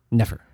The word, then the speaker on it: Nefer: Here we have, our pal, Tie-Dyed Vortex, actually said the pronunciation of this, NFR, Nefer? – Nefer, yeah.